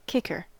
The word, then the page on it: kicker: American English